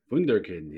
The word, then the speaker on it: Wunderkind: Wunderkind, yeah.